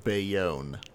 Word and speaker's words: Bayonne: It's pronounced Bayonne.